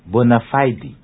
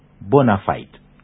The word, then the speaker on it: bona fide: The correct pronunciation is bona fide, not bona fide.